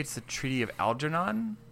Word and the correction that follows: Algeron: I'm going to give it to you because of your pronunciation is a little off, but I'm going to give it Algeron.